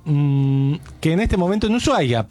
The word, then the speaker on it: Ushuaia: Igual son creencias, porque viste, a veces de golpe entras, Ushuaia, sí. – Que me encanta que se escriba SH, pero no se pronuncia, la H es muda.